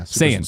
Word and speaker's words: Saiyan: Am I saying it right? S-A, because it's like more Cyan, Saiyan. Right? Cyan. Saiyan? – Yeah. Saiyan. Super Saiyan. – Well, that doesn't help me. S-A-I-Y-A-N 1990.